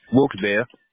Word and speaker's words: Wilkes-Barre: You all pronounce it Wilkes-Barre, right? Or Wilkes-Barre. – Wilkes-Barre. – There we go.